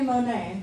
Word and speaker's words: Monet: And it rhymes with my name, Monet.